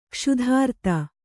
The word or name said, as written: ಕ್ಷುಧಾರ್ತ